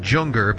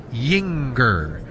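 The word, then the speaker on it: Juenger: Spells it with a J, J-U-E-N-G-E-R. Looks like Junger, but it's pronounced Yinger.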